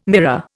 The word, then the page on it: mirror: Indian